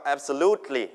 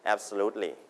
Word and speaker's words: absolutely: absolutely พยางค์ที่ 3 เน้นนะครับ absolutely